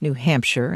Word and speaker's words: New Hampshire: The lawmakers also want to include an official pronunciation of New Hampshire and state law, making it clear sheer rhymes with fur.